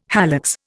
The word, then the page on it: hallux: Indian